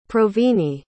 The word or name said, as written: provine